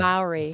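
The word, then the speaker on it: Māori: And if you don't know what we're talking about, this is, I want to pronounce it correctly, the Mora tribe? – Maori tribe. – The Maori. – Thank you for correcting me.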